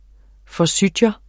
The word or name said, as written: Forsythia